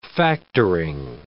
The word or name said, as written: factoring